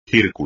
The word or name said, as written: círculo